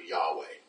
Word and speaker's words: Yahweh: And then there's the actual name of God, which is would be pronounced something along the lines of Yahweh.